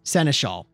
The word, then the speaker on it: Seneschal: You encounter a godlike being called the Seneschal. Is that how you pronounce that? – Yeah, Seneschal? – I think you're right.